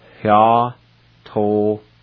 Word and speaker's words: yatotli: And before I pronounce this, let me add, there's an H sound right at the very beginning, right before the Y. So you kind of make a little H sound, and it sounds like this. Hya-to-kli.